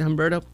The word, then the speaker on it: Humberto: Is that how you say it, Humberto? – Sounds good.